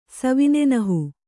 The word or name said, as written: ಸವಿನೆನಹು